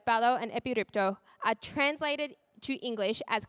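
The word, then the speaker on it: epiripto (ἐπιρρίπτω): However, the word that Peter chooses here is the Greek word epiripto. Did I say that right, Mum? Yeah. Fun fact, my mum can speak Greek, so I checked.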